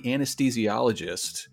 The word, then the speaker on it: anesthesiologist: And like Kate, you are a practicing anesthesiologist,